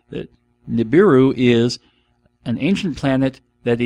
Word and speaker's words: Nibiru: In the pseudoscience corner, I don't even have any idea how I came across this, but I came across the planet of Nibiru, I think is how it's pronounced, N-I-B-I-R-U, Nibiru.